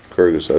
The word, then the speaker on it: Gergesa: Gergesa. – Okay, spell that, please. – G-I-R. – G-E-R. G-E-S-A. G-E-S-A.